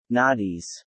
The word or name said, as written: noughties